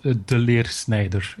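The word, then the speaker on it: De Leersnijder: Yeah, it's a challenge for English-speaking people. So it's called De Leer Snijder. It's Flemish, yes.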